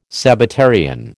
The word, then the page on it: Sabbatarian: American English